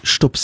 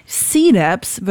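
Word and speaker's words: Cdubz: C-Dubs wird er ausgesprochen. Das C wird einfach ausgesprochen wie das englische C halt.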